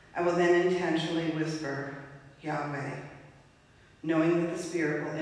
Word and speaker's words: Yahweh: That name is Yahweh. The commentary I read suggested that inhaling was the first syllable of Yah, and the second syllable, Wei, is the exhale.